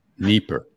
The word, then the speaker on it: Dnieper: All right, I pronounced it Dnieper. Is that wrong? All right, got this feedback is. – I call it Dnieper, but. – All right, you want to weigh in on that? What's the right pronunciation? Do you have a vote? Okay. – Dnieper. – Dnieper. Okay. – Yeah, E like Irina.